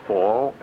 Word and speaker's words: Fall: My name is William Fall, F-A-L-L, as in the season of the year.